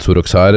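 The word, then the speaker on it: Soroksár: Ja, Shwakvari mot Soroksar. Etter hva jeg vet, så er det perfekt ungarsk uttale.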